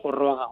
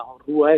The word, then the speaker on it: Orroaga: Por ejemplo, en Guetaria también, o Rúa, se suele decir, pero la documentación nos muestra que la forma completa es Orroaga. Orrúa es la pronunciación local de ese Orroaga. Entonces,